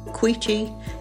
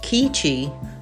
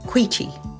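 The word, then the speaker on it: Quechee: Queechee is often pronounced Keechee. It is Queechee.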